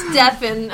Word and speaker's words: Stefan: It's Stefan. – Not to be confused with Stefan, which rhymes with friend.